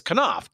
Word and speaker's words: Knopf: I never can pronounce that correctly because I always get screwed up on it. It's Knopf, K-N-O-P-F, a long time and major imprint in the publishing world.